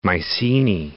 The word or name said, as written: Mycenae